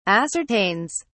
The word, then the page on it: ascertains: British English